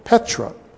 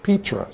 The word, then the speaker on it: Petra: There is an ancient city in Jordan called Petra, or Petra, sometimes it's pronounced, P-E-T-R-A.